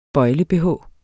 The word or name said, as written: bøjle-bh